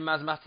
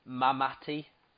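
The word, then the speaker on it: Msamati: I think Lucian Masmati in this, I don't know if I'm pronouncing that, I think it's Mamati. It's got a silent S, which throws me.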